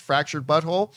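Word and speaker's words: Fractured But Whole: And I don't know if you guys have played either of their video games, Fractured Butthole. It's actually Fractured Butthole, W-H-O-L-E, but it's pronounced Fractured Butthole.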